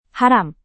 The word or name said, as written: haram